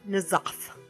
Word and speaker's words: N'Zoth: I think that you left the next one for me is because I can never pronounce these things. Tentacle of N'Zoth. – Sounds like you said it right.